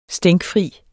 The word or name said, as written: stænkfri